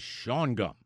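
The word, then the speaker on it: Shawangunk: The correct pronunciation is schwangunk.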